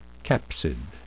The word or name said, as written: capsid